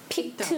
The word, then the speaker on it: picked: The pronunciation picked is picked or picked? – Picked it up. With P-I-C-K-E-D, it sounds more like a T. Sometimes E-D sounds like a T. Picked.